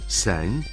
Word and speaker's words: 三: Слово «сань» произносится первым тоном, означает «три».